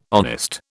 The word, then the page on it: honest: British English